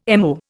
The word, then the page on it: MO: Indian